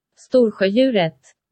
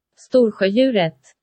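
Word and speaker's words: Storsjöodjuret: Storsjöjuret. – Whoa, for fuck, what? – Storsjöjuret. – Stutjudret. Nope, hold on. – Storsjöjuret. – Stutjudret. Look, it's spelled Stors Jude Juret, but it's pronounced Storsjöjuret.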